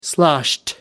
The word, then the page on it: sloshed: American English